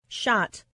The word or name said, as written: shot